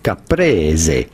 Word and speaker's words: caprese: How do you go about pronouncing it? In Italian, caprese.